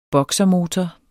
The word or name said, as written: boksermotor